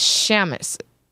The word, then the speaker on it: Seamus: When you said my name on the live stream in the Faux Show 136, which was last week's episode, you pronounced it the way it's spelt, but it's the Irish spelling, so the EA makes an SH sound. So in American, my name is Seamus.